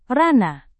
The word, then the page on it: ranah: Indonesian